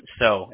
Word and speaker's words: Seo: John So. It's pronounced So. I know it's spelled S-E-O, but, you know, it's So.